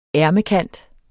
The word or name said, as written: ærmekant